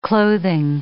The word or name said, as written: clothing